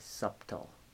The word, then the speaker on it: subtle: No, but I think it has a subtle silent B in there. – Really? – Yeah, so I think you need to go easy on it.